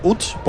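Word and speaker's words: Łódź: El ex jugador del Vitzeo de Utz, aunque se escribe Lotz, se pronuncia Utz, polaco.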